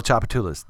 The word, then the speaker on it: Tchoupitoulas: Chapatulas, can you spell it? – Hell no, I can't spell Chapatulas. – T-C-H-O-U-P-I-T-O-U-L-A-S.